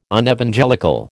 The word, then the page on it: Unevangelical: American English